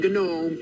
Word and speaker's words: gnome: First of all, if you were a gnome, you would know that the G in gnome is silent. It's gnome, okay? – No, it's cannot.